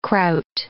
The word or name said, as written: kraut